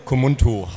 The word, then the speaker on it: Kubuntu: Bei mir ist Projektentwickler und das ist ja schon, ich habe es ja gerade schon falsch ausgesprochen. Ja. Sag es vielleicht nochmal richtig oder erklär mir mal, was das überhaupt ist. – Kubuntu heißt es.